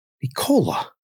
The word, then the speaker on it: Nikola: How do you say Jokic's first name correctly? – Nikola.